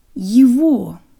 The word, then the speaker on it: его: Его.